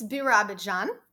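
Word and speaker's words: Birobidzhan: It's Birabidzhan. That's the pronunciation of it.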